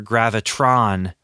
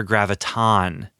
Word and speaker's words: Graviton: it's not Professor Gravitron in the comics, it's Professor Graviton. Graviton, not Gravitron.